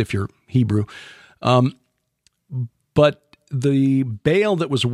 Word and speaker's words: Baal: My talk at Morningside Church last week dealt with Elijah's confrontation with the priests of Baal. Is the correct pronunciation, just for you scholars out there.